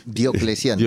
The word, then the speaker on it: Diocleciano: Eh, la semana pasada creo que hablábamos de Diociclano no sé si lo he pronunciado bien. Diocleciano. Diocleciano.